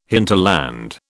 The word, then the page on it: hinterland: British English